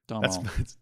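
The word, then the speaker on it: Domhnall: As many people reminded us, you don't pronounce the M in Donald Gleason. – Donald.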